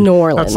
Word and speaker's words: New Orleans: New Orleans. – That's like pronouncing it Oregon. – I think it's Nolens. – Nolens. – Please don't ever say that again.